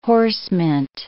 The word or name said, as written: horsemint